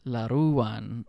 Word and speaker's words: Laruan: Uh, a basic a basic rule for Tagalog words is um, it's the second syllable that has the emphasis. Laruwan.